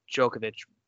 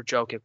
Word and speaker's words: Jokic: If he doesn't win MVP this year, I get Djokovic, or Jokic, whatever is how you pronounce his name, was, yeah, kind of Jokic, yeah.